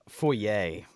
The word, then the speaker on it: foyer: The proper pronunciation is foyer, by the way.